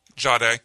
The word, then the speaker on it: Jahdae: Jahdae.